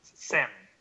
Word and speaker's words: salmon: You pronounce the L in your Salmon? – No, Salmon. – There you go.